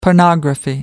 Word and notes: pornography: American English

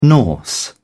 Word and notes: Norse: British English

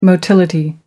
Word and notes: motility: British English

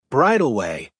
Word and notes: bridleway: American English

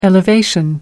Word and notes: elevation: American English

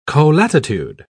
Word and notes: colatitude: British English

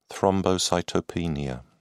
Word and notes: thrombocytopenia: British English

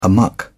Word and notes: amuck: British English

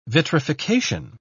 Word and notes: vitrification: British English

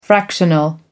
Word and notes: fractional: American English

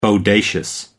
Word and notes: bodacious: American English

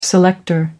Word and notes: selector: American English

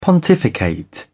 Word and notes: pontificate: British English

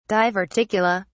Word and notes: diverticula: British English

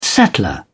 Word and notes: settler: British English

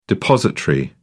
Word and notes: depositary: British English

